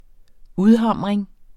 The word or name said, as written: udhamring